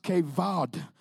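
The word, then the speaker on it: kavod (כבוד): The word glory in the Hebrew is kavod, K-A-B-O-W-D, but it's pronounced kavod.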